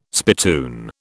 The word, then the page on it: spittoon: British English